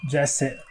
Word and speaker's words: Jesse: Jesse Eisenberg, é assim que se pronuncia? – É, Jesse, é.